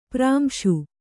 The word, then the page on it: ಪ್ರಾಂಶು: prāmśu